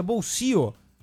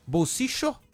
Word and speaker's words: bolsillo: O caçapo em espanhol, que se pronuncia bolsio. Que tem as três pronúncias do duplo L lá na Argentina, isso. – Né? Bolsicho, como se fosse um CH, né?